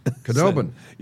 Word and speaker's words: Khudobin: Khudobin.